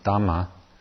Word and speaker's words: Dhamma: In Pali, it's pronounced Dhamma, and in Sanskrit, it's Dharma.